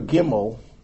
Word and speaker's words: Gimel: Now, I don't know if this is true or not, but if you take the letter Gimel, it's a hard G, and Dalit is a D.